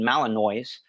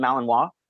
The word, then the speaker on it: Malinois: They're like, I have a Belgian Malinois.